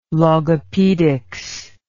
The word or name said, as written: logopedics